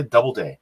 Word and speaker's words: Doubleday: We're going to be talking with Miss Julia Doubleday. Did I say your last name correctly? I just want to make sure. – Yes, I did. – Yes, you're perfect. Doubleday. Just okay. Like it's spelled.